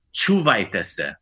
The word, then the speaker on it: čuvajte se: So the formal way of saying it is čuvajte se. – Čuvajte se. – So let's put the accent on the first syllable. Čuvajte se.